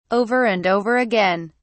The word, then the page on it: over and over again: British English